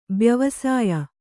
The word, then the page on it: ಬ್ಯವಸಾಯ: byavasāya